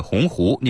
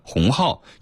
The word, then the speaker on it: 鸿鹄: 在演讲中，林建华将立鸿鹄志中的鸿鹄念成了鸿号，引发了广泛关注。